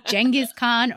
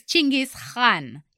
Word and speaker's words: Chinggis Khan: Many might know him as Genghis Khan or Genghis Khan, but his real name is Chinggis Khan. It's not K-H-A-N. The Han is pronounced as, you know, that sound. – Khan. – So Chinggis Khan.